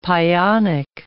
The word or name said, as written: pionic